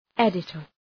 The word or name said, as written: editor